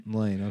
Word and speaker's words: Lain: Lain, okay.